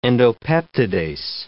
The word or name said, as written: endopeptidase